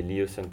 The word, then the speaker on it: Lyosun: Alors, Léoson, ça s'écrit L-Y-O-S-U-N, donc c'est un petit peu bizarre. Il y a des gens qui peuvent le prononcer Léoson aussi. Moi, j'ai décidé Léoson parce que je trouve que c'est plus, ça sonne mieux, en fait, et c'est plus facile à prononcer aussi.